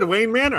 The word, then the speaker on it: Wayne Manor: The bat signal hits the air, and then Main Wanner. – No, you said Wayne Manor. I thought you said it right. – I feel like I said Main Wanner.